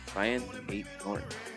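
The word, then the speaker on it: Aït-Nouri: It pretty much is straightforwardly Ryan eight, Ryan eight, eight Nori, Ryan eight Nori. The eight Nori is pretty close.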